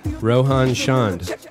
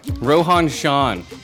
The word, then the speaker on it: Rohan Chand: Rohan Chand. – Rohan Chand.